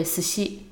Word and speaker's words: 寿司: 哦寿司那个单字，然后拼成罗马拼音是 S U S H I，所以 U 的地方也是被 S 跟 S H 夹住了。那、嗯、后面的西也是一样啊，所以寿司应该是轻轻的发音。